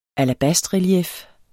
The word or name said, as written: alabastrelief